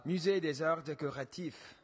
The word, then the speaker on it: Musée des Arts Décoratifs: Oh, uh, Musée des Arts Décoratifs.